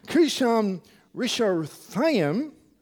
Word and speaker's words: Cushan-Rishathaim: Now, apparently this name, Cushan-Rishathaim, how do you say it? Cushan-Rishathaim, there's a slight variation.